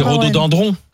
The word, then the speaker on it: rhododendron: Deux secondes, parce qu'on dit Rhodo. – Dindron. – C'est piégeant. – C'est dendron. Alors c'est rhododendron. – Ah bon, oui. – Est-ce qu'on y a... Ça s'écrit dendron.